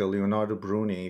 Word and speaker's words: Leonardo Bruni: And also, you mentioned an important character, Leonardo Bruni, if I'm pronouncing the yes.